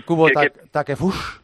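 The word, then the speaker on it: Kubo Takefusa: ¿Se dice bien Kubo Takefush? – Sí, sí, tú pronúncialo exactamente igual que lo leas.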